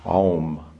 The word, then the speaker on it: Om: So all the possibilities of sound, shabda, all the possibilities are contained within that flute becomes Om, then Om enters this universe and expands into all the forms of sound that we hear in this universe, from A to M. A-O-M, Om.